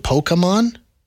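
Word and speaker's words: Pokémon: Am I saying it right? Pokemon? – Sounds right to me.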